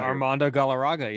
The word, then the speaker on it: Galarraga: Obviously, the 2010 Detroit-Cleveland, I always try to pronounce this name correctly, and then Galarraga. – Armando Galarraga.